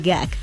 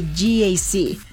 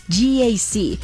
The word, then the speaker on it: GAC: No se dice GAC, se dice GAC. Nuevamente, GAC. Y se maneja mejor de lo que se pronuncia.